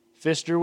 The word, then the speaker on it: Pfister: Christy Mathewson pitched for New York, and Jack Pfister with a P. Literally, it's spelled P. Pfister for the Cubs.